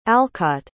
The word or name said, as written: alcott